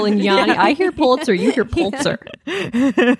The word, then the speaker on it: Pulitzer: Pulitzer.